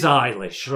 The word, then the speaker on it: Eilish: It is Eilish, right, because I always say Eilish.